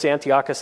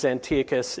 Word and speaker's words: Antiochus: So Antiochus, he was sometimes pronounced Antiochus, sometimes Antiochus.